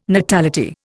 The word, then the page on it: natality: Indian